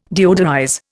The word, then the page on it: deodorize: Indian